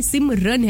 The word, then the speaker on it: सिमरन: भाई रन नहीं है, सिम रन नहीं है, सिम रन है, RAN.